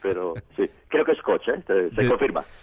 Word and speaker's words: Koç: One of the presidents of a Turkish university, the University of Koç, how do you pronounce it? – Yes, in Turkey, it's Koç. – Koç, thank you.